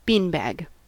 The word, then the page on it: beanbag: American English